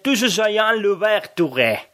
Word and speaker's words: Toussaint Louverture: O, por ejemplo, el nombre del gran libertador de los negros, Toussaint Louverture, en realidad, en criollo, vendría a pronunciarse algo así como Toussaint Louverture.